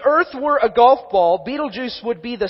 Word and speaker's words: Betelgeuse: And there's another star that is, I'm not even going to try to spell it for you, but it's basically pronounced like Betelgeuse.